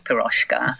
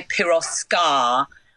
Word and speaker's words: Piroska: But ours is spelt slightly differently because the S in Hungarian is on its own just sh. So we put the H in because I thought I can't be bothered to fucking explain to people how to pronounce it. If it just said, they'll just go Piroska.